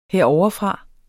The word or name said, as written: herovrefra